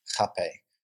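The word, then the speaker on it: rapé: So, we have this Hape, it's spelled R-A-P-E, but it has I think it's Rape. – It says pronunciation here, H-A-P-A-Y, Hape, right?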